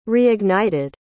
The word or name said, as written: reignited